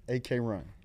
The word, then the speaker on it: Akron: Akron, AK Run. – AK Ooh. Run. That's how they say it.